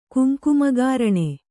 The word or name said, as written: ಕುಂಕುಮಗಾರಣೆ